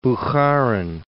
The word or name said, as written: Bukharin